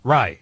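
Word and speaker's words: Rai: Yeah, Aaron Rau. Is that how you say his name? – Rye. – Aaron Rye.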